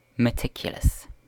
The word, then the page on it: meticulous: British English